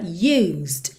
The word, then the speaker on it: used: E atenção, você não vai cair em pegadinha aqui porque já tá pintadinho de cinza para você o E da palavra used, used. Então você nunca vai dizer used. Para quem tá ouvindo no podcast, essa palavra se escreve USED, o E não é pronunciado, ok? Absolutamente, você não fala nada desse E.